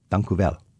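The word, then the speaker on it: Dank u wel: Dankuvel.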